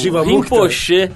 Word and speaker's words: Rinpoche: É, como é que é o iluminado no, no budismo tibetano, Arthur? É o Rinpoche. Rinpoche.